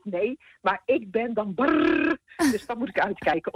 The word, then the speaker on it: bar: Als mijn regering boos is op mij, dan zegt hij tegen mij: jij bent brrr. Dus B-A-R, maar dat moet dan met een rrr worden uitgesproken.